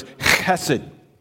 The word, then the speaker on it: chesed: In the Hebrew, it's like you say human, and you emphasize the ch. So it's chesed.